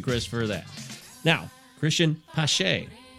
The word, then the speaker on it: Pache: Is it Pache? – Pache, yeah. – Pache. – Yeah, so kind of pronounce it like there's an S in there, like Pache.